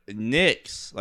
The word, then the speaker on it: Knicks: The Knicks is spelled with a K, but it's pronounced with an N. – That is completely different. – It is not. – Nick, you know, because the N is silent. Or no, the K is silent.